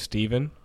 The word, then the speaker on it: Stephon: So my middle name's Steven, but it's spelled Stephon.